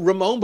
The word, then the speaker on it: Ramon: uh, Ramon, but I'm fine with Raymond. But Ramon,